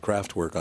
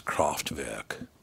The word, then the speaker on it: Kraftwerk: But I played Kraftwerk, and I came back and said, Kraftwerk on blah, blah, blah. And the music director comes in and goes, Tom, that's actually pronounced Kraftwerk.